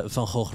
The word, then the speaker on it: Van Gogh: I'm just going to keep to the Dutch pronunciation here because... Van Gogh really doesn't cut it. The Van Gogh reference on your album cover there, I really love the aesthetic of it.